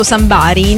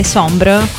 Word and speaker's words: Sombr: E praticamente l'ha chiamato Sombr. Quindi non l'ha chiamato Sambari, noi lo chiamiamo Sambari, però dovrebbe essere Sombr. Quindi senti, correggiamo subito la pronuncia.